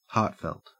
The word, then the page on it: heartfelt: Australian English